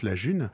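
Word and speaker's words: Ğ1: La June, qui s'écrit donc G avec un accent un petit peu bizarre, hein, mais qu'on prononce la June, c'est une monnaie électronique, on parle aussi d'une crypto-monnaie.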